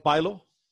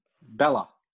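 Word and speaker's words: Bálor: It's not Balor. – Bella. Finn Bella. – Thank you. All right. I tell you who had a fantastic day in the ring is the new NXT champion, Finn Bella.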